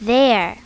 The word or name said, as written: their